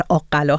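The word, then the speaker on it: آق‌قلا: البته فکر میکنم تلفظ این شهر آقلا هست از سابقه قطع من اون رو تلفظ کردم، در واقع یکمی دچار اشتباه شدم.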